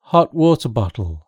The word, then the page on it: hot water bottle: British English